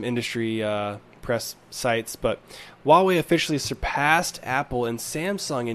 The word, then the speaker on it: Huawei: So, starting us, kicking us off here, really quick, want to want to just shout out Huawei. That's how you pronounce it. H U A.